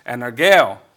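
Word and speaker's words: energeo (ἐνεργέω): It would be pronounced energeo in Greek,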